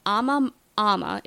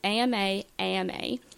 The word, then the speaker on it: Ama-Ama: Okay, so you have the, I'm going to pronounce all these wrong, but Ama-Ama. It's two A-M-A-A-M-A.